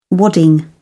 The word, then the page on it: wadding: British English